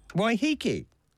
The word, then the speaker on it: Waiheke: And this cluster just north of Rangitoto and north of Waiheke. Waterspout there heads up north in Waiheke in the next hour. – Waiheke.